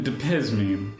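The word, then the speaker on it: Diazepam: How do you pronounce it? Dapazamine? – Dapizmine, yes.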